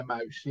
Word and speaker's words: Muijs: Did you say, is that how you pronounce his name? – Yeah, Mersch. Yeah, the UI sound in the lowlands is one of the hardest.